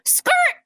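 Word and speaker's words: skrt: But have you heard it when people go, skirt? – Yes.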